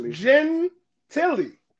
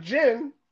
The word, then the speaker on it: Gentilly: It's pronounced Gentilly. It's like Gentilly.